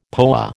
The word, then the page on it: POA: American English